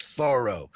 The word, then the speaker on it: Thoreau: I love this quote from Thoreau, or I visited his place, and they called it, it's Thoreau. It's pronounced Thoreau.